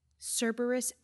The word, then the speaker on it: Cerberus: Is that how you say it? Cerberus? – Yes. – "Cerberus Action, after the mythical three-headed dog that guards the underworld."